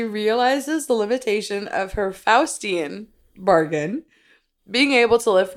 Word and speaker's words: Faustian: As she realizes the limitations of her Faustian bargain, is that how you even pronounce that word? I'm pretty sure that's Faustian, but keep going.